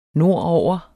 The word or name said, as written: nordover